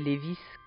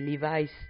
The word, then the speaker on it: Levi's: comme les Lévis qu'on aurait dû prononcer Levi's depuis le début